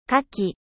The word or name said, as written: kaki